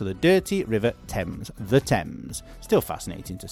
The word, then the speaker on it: Thames: The spelling is T-H-A-M-E-S. No, it's not Thames. It's Thames. Basically, the pronunciation is Thames, as if it's a T-E-M-Z, let's say.